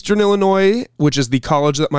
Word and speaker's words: Illinois: I was born in Illinois, and it's Illinois singular. There's no S.